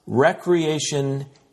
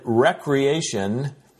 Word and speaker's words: re-creation: When we pronounce the word recreation, we are more likely to think of summer games, parks and trails, campgrounds and picnic shelters.